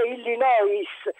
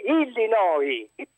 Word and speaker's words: Illinois: Non si dice Illinois, si dice Illinois, per esempio.